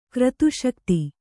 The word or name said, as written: ಕ್ರತುಶಕ್ತಿ